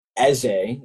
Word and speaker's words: Eze: Yeah, I'm Igbo, and so in the Igbo language, Eze, which is the actual pronunciation, means king, and so.